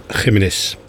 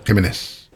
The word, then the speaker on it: Jiménez: Flaco Jiménez. Zo moet je het uitspreken, hè, geloof ik. – Ja, Jiménez. – Jiménez.